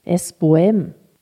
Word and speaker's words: Sbohem: Und damit sage ich probiere das jetzt mal auf Tschechisch, Sbohem. Richtig ausgesprochen? – Sbohem, ja.